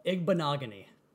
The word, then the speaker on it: Igbinoghene: But as the Auburn corner, what do you, Noah Igbinoghene. – Igben, and I know his parents are both incredible athletes as well, and his story is great.